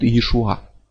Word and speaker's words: Иешуа: Вот так же, как можно сказать Иисус, а изначальное имя, строго говоря, звучит Иешуа.